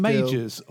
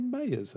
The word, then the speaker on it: Majors: Is it pronounced Majors or Mayors? – Majors. – That's what I thought was pronounced Majors, but someone said to me Mayors earlier. I thought I'd never even thought of pronouncing it like that. – No, I think it's Majors.